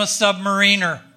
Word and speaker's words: submariner: That's when I joined the Navy to become a submariner. And yes, that's how you pronounce that word.